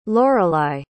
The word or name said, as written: loralei